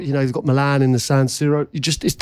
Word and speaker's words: San Siro: How would you say it? – San Siro. – Right. Yeah. So you got that right.